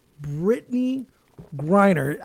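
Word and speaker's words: Brittney Griner: Brittany Griner.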